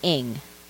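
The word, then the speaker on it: Ng: So, and her last name is pronounced Ing, so Celeste Ing.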